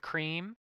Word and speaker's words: Cream: My name's Charlene Cream. – Now, Miss Charlene, how do you spell that? Like the milk? – Yes, sir. C-R-E-A-M, just like it sounds.